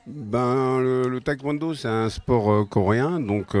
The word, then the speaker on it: taekwondo: Maintenant, on va parler du taekwondo, si je le prononce bien. C'est bien ça? – Oui, c'est ça, oui. C'est le taekwondo.